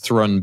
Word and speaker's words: Thrun: Henry, I presume it's pronounced Thrun, by the way, it's T-H-R-U-N.